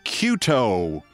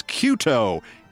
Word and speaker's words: Cueto: saluting Chicago White Sox pitcher Johnny Cuto mispronounced his name like that three times in 30 seconds. Cueto.